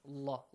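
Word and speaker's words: Allah (الله): The rule says, if there is a dhamma sound or a fatha sound, pronounce the letter lam in the word Allah, heavily with the law sound. Allah, Allah.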